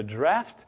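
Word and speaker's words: draught: Do not ye yet understand that whatsoever entereth in at the mouth goeth into the belly and is cast out into the draft? That is a British spelling. We spell it D-R-A-F-T. We pronounce it the same.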